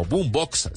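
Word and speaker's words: Bumbox: Nos pueden encontrar también en las redes como Boombox, se escribe B U M B O X, B U M B O X Boombox, así todo pegadito y se van a encontrar con todos estos podcasts y con todos esos podcasters.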